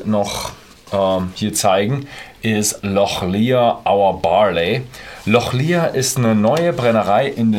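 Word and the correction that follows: Lochlea: Eigentlich heißen sie Lochlee, spricht man es aus.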